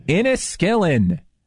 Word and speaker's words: Inniskillin: Yeah, it's, but it's called a certain, I don't know if I'm saying it correct, so insulin, right? Is that how you say it? Iskinolin or something like that? Insulin? – Well, I mean, if you're going to chug insulin, what happens in Vegas and so forth? Inniskillin. – Inniskillin.